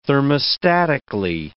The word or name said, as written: thermostatically